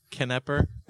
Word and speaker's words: Knepper: Knepper. I'm guessing I'm sure the K is Knepper. Silent.